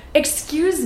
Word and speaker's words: excuse: So, pay special attention to the Y sound that gets added, even though there is no Y in the word excuse. So listen, excuse. Do you hear the Y?